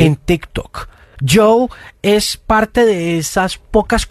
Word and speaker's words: Djo: D-J-O se escribe, pero se pronuncia Joe.